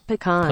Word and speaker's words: pecan: In French, they emphasize the second syllable, which is what we're doing. We're saying pecan. – Mm-hmm. – But in English, most words emphasize the first syllable.